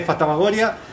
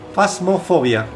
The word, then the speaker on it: Phasmophobia: Hoy tenemos un programa lleno de America Power y vamos a hablar de Fantasmagoria, además del juego de la semana y noticias. – Fasmofobia.